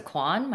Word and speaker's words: Kwan: So Melissa Kwan, my last name is spelled K-W-A-N.